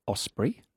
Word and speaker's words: osprey: I think it's osprey. – Is it really? – It might be. – Not osprey. Osprey.